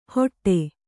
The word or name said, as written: ಹೊಟ್ಟೆ